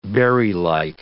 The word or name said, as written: berrylike